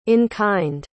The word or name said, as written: in-kind